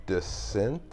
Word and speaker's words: Dissent: I'm the host, and I'm here with Johnny Dissent. – That is the correct way to say it. Yeah, dissent, like disagree or to withhold approval of.